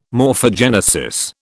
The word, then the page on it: morphogenesis: British English